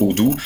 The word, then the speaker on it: Owdoo: Et sur la partie communication, nous avons souhaité regrouper l'ensemble des enjeux de mobilité à travers cette marque Odoo. Oui. Alors là, c'est O-W-D-O-O, donc c'est la prononciation littérale. Oui. Quasiment du phonétique,